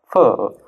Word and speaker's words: pho: Excuse me if I like pronounce this wrong, but I've been like wanting to eat a nice, you know, pho. Okay. Is it okay, Fa? – Yeah. So the right pronunciation is really difficult. It's like pho.